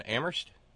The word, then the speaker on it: Amherst: And Terry in Amherst, where only the H is silent.